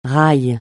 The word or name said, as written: raï